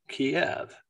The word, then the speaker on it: Kiev: I'm in what some people call Kyiv and others call Kiev. Stu, I like me some chicken Kiev, so I'm not changing the way I pronounce it. It's the way I've always said it, and everybody knows what the hell I mean. Even though I know that's the Russian way of saying it, and for that, I will probably be attacked as a Russian sympathizer.